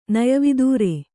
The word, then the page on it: ನಯವಿದೂರೆ: Kannada